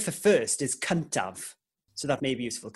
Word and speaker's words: Cyntaf: Cuntav. – Perfect. – That's the first on the podcast. – If Jordan wins... – Jordan will be cuntav. – Yes, I've said it for years. – Absolutely. And it's spelled C-Y-N-T-A-F, in case you were to want to write that down.